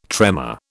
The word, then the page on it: tremor: British English